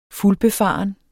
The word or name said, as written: fuldbefaren